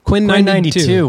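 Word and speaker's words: Quinn XCII: How do you say it? – Quinn 92.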